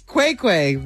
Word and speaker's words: Qai Qai: The real, how do you say it? Real Quake? – Oh, Quake Quake. Real Quake Quake. – Okay, Quake Quake. The real Quake Quake. Q-A-I-Q-A-I.